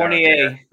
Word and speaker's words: Fournier: Fournier. It's like Battier. Remember Battier? – Yeah, that's great.